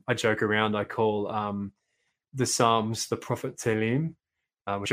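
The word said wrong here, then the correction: Tehillim (תהלים): There's an extra syllable kind of hidden in there, Tehillim, but I can't say it properly.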